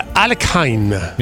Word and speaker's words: Alekhine: Alekhine. – Ah. – No, eso me tiré hasta pronunciarlo bien.